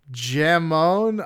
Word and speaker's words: jamón: B, 5.6 Iberian ant harvester thingamajigs go into the diameter of a Spanish jamón ibérico slice.